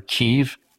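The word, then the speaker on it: Kyiv: Kiev or Kiev, the Ukrainians pronounce it Kiev.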